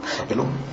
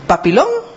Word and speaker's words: Papillón: Papilón. – ¿Papilón? O papillón, papilón, ay, qué horror. Ya, disculpen, se escribe así, pero se pronuncia papilón.